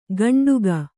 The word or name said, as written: ಕಂಡುಗ